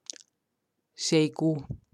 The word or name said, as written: Zeekoe